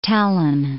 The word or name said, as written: Tallinn